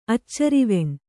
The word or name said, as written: ಅಚ್ಚರವೆಣ್